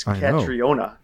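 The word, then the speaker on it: Caitríona: There's an I and an O in there. It's Katriona. Katriona Balf. I'm going to go that pronunciation.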